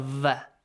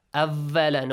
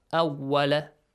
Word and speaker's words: Awwala: Make sure you pronounce the waw as well correctly. It's not va. Awwala, no. Awwala.